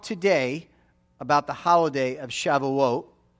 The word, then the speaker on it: Shavuot: You can pronounce it different ways, but we pronounce it kind of the Sephardic way here. If you're Ashkenazi, it's Shavuos or Shavuos, but it's Shavuot. You know, I pronounce it Shavuot.